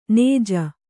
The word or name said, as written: ಞ